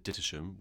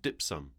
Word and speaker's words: Dittisham: But we don't pronounce it Dittisham, we say Dipsum.